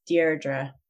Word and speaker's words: Deirdre: It's Deirdre. Oh, it's butchered over here as well. It's an Irish name, actually. And so English people really struggle to get it right.